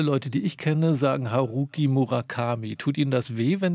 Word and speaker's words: Haruki Murakami: Die jetzt von unserer großen ARD-Aussprache, Datenbank empfohlene Aussprache ist Haruki Murakami.